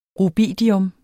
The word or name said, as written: rubidium